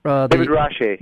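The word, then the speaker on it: Rasche: And then David Reich. Am I saying that right? – David Rache, who does this brilliant.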